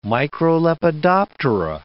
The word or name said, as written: microlepidoptera